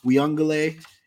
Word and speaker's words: Uiagalelei: So DJ Uiagalelei, I said that right. That's right, baby. I learned it. Yeah, I know. I've been calling him DJ too because I couldn't pronounce his name. So DJ Uiagalelei, you know, he's super talented, man.